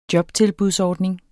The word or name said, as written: jobtilbudsordning